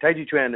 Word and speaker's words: Taiji Chuan: Taiji Chuan is the Chinese pronunciation of Tai Chi, what we more familiarly know in the U.S. as Tai Chi.